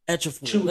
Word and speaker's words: Ejiofor: Say his last name and you good. – Okafor? I can say that. But that's not how you say his name. – Say it. Sound it out, though. – Etchafor. – There you go.